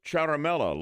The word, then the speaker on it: Ciaramella: is a man by the name of Eric Ciaramella. C-I-A-R-A-M-E-L-L-A, we're told pronounced Ciaramella.